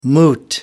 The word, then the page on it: moot: American English